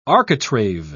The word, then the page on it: architrave: British English